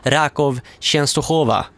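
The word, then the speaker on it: Raków Częstochowa: Det var ett fotbollslag som jag skulle uttala. Rakow Częstochowa fick jag lära mig att det hette.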